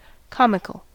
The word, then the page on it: comical: American English